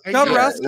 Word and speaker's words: Jodorowsky: Wait, did you say Jabberwocky's Dune? – Am I saying that wrong? – Jodorowsky? – No, you're definitely saying that wrong.